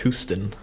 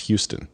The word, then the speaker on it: Houston: There's a street in New York City called Houston Street, spelled Houston. – Aware of this. – Like the city in Texas, which is pronounced Houston.